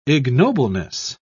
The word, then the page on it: ignobleness: British English